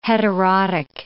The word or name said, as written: heterotic